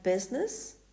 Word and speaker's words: business: It also becomes silent after the letter T, like in debt, D-E-B-T, or doubt, D-O-U-B-T. The letter I is silent in words like business, B-U-S-I-N-E-S-S, suit, S-U-I-T, and F-R-U-I-T, fruit.